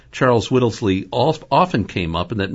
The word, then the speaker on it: Charles Whittlesey: And this Charles Whittlesey, I'm pronouncing the name right? – Yes, Charles.